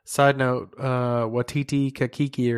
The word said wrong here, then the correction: Taika Waititi: or however you say his name, the guy that directed Ragnarok, voiced that robot. Taika Waititi.